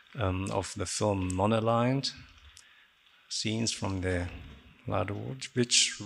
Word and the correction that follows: Labudović: Reels. I've got the name wrong, sorry. – Labudović Reels, right?